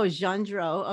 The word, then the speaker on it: Gendreau: Did I pronounce it right? – Gendreau.